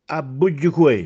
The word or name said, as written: Abbuggecuè